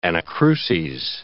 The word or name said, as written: anacruses